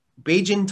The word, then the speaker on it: Bajan: How do you pronounce the name of the sauce? – Beijin Tiger. – Beijin Tiger. – Here's the story behind it. Beijin is the term you would use for someone from Barbados.